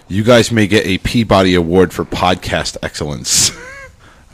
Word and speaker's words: Peabody: In Massachusetts, they pronounce it Peabody.